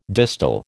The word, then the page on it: distal: American English